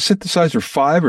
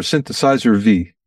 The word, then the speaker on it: Synthesizer V: Do you pronounce it Synthesizer 5 or Synthesizer V? – I would pronounce it a V, although it actually means 5.